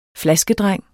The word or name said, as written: flaskedreng